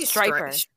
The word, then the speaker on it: Stryper: How would you say that? – I'd say striper. Striper.